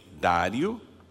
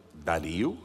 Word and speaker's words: Dario: Este Daniel, pois, prosperou no reinado de Dário, ou Dario, né, conforme o local, a pronúncia, etc. Eu acho que é Dário, muita gente fala Dario, eu também às vezes falo Dario, então nós podemos falar as duas formas.